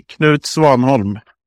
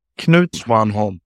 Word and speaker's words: Knut Swanholm: But yeah, the correct pronunciation would be Knut Swanholm. – Okay, let's get that one more time. – Knut Swanholm.